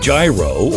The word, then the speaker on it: gyro: How do you pronounce the word G-Y-R-O? Whether you say gyro or gyro, it doesn't matter.